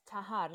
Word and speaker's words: Tahjar: Yes, Tahar. – Okay. Um, Okay. I, yes, the J is silent.